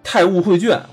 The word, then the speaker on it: 太吾绘卷: 我们就是来想一起聊聊这个最近特别火的游戏，就是这个《太悟绘卷》。我操，我老念成太污绘卷，对我也念错好多次了。